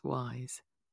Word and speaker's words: Weyes: So any listeners who aren't familiar, it reads as Wise Blood, W-E-Y-E-S, but it's pronounced Wise. Blood.